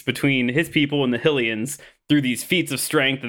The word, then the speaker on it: Hylians: Is that how you pronounce the Hylians? – Hylians. Like helicopter.